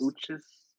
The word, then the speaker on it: Uchis: I think I said that name wrong. – It's not, I always pronounce it just Kelly Uchis, but that could be all the way wrong. – Uchis. Is it Uchis? – Uchis.